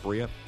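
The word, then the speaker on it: Barría: Barea, I believe, is pronounced. – Yep. – Barea.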